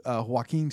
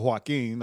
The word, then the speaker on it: Joaquin: Oh, I'll spell it W-H-A. – Yeah, and we try to do joking. – Joking. – Right, for Joaquin, like joking, but taken.